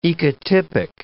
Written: ecotypic

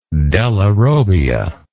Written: della robbia

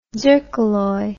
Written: zircaloy

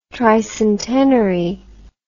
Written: tricentenary

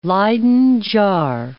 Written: Leyden jar

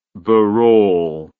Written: burrole

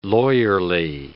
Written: lawyerly

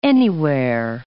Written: anywhere

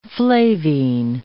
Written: flavine

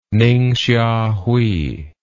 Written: ningxiahui

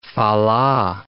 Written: fa la

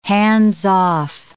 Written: hands-off

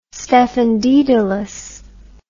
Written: stephen dedalus

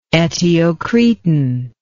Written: eteocretan

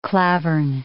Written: klavern

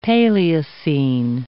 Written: Paleocene

